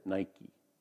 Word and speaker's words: Nike: We pronounce it Nike, like the shoes. That's what the word Nike means, by the way.